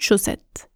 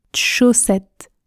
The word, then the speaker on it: chaussette: Et enfin, jus de chaussette se prononce jus de chaussette. Je ne prononce pas le e du mot de chaussette, chaussette, jus de chaussette, jus de chaussette.